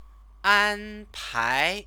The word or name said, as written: anpai